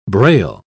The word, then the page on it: brail: British English